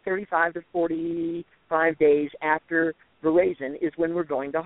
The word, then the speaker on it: veraison: If you speak French, it is veraison. But if we're American, it's pronounced veraison, hence veraison vineyards and winery.